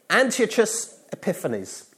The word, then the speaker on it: Antiochus Epiphanes: So after a few years of this Syrian rule, the king of the Seleucids was this guy, Antiochus Epiphanes. That is the correct way of pronouncing it. I even got the accent right.